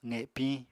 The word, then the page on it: qepiñ: apatani